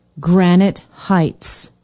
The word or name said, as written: granite heights